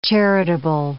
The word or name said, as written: charitable